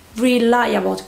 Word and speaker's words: reliable: Cuidado con la pronunciación de esta también, que es rely, viene del verbo rely, reliable, que sería alguien fiable, alguien de quien te puedes fiar.